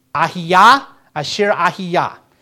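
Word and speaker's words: Ehyeh Asher Ehyeh: The Hebrew pronunciation is Ayahah Asher, let me pronounce it again, Ahiyah Asher Ahiyah.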